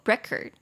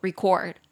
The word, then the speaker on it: record: 名词念作 record，动词念作 record，对不对？完全不一样。